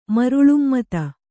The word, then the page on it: ಮರಳುಮತ್ತ: maraḷumatta